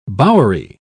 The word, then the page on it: bowery: British English